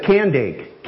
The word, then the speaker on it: Kandake: It said Candake. K-A-N-D-A-K-E. Did I spell it Candake? Yeah. Candake. So it was actually a title, not necessarily that person's name.